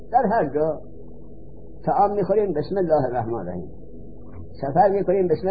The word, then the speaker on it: بسم الله: همزه را نوشته به اسمه، لیکن تلفظ نمی کنیم، بار بسین وصل میکنین در تلفظ. بسم الله با وصل شد بسین، اما نوشتنش می نویسین، تلفظ نمی کنین.